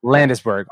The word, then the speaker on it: Landesberg: Is that how you pronounce it? Landesberg? – But I'm pretty flexible. – Landesberg.